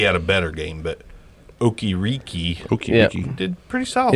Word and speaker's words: Okereke: Bobby Okereke, is that how we pronounce that? – Okereke.